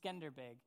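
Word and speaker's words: Skanderbeg: All right, now, Jeff's nodding at that I pronounced it right. First service, I didn't pronounce it right. But it's Skenderbeg.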